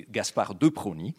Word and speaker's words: Gaspard de Prony: Uh, back in 17, sorry, 93, uh, the Baron de Prony, Gaspard de Prony, I should probably pronounce it the French way, Gaspard de Prony, uh, uh was somebody who invented a factory like structure where he recruited unemployed hairdressers uh, to, to do what?